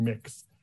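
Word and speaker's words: Mx: I identify as non-binary, and rather than the term Mr., Mr. or Ms., I use the term mix, spelled M-X, pronounced like the word mix.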